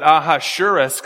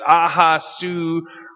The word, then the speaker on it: Ahasuerus: And so verse 1, in the first year of Darius, the son of Ahasuerus, that's hard to say. I usually pronounce it wrong. I was going to try to pronounce it better today. I usually pronounce it Ahasuerus because that's just easier for me to say, but it's Ahasuerus, and I'm going to mess that up a few times as we continue on.